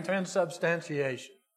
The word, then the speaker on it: Transubstantiation: Transubstantiation.